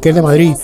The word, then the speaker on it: Madrid: Y en Madrid, los madrileños, por regla general en Madrid, no se pronuncia la D, para nada. Tenemos muchísimos fallos, yo sobre todo, de cuestión lingüística, y una de ellas es que no pronunciamos la D.